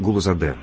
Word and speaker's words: Гулузаде: Галузаде, если я не ошибаюсь, да? Правильно сказал? – Да, немножко вы говорили именно с московским, я бы так сказал, акцентом. Вафа Мирзоевич Гулузаде, правильно? – Ну, ради бога, извините, пожалуйста, не хотел обидеть.